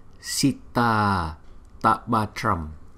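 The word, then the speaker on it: Sitātapatram: Cái hai chữ A này chúng ta nói liền với nhau thành một chữ A thôi, cho nên chúng ta đọc là Sita Tapatram.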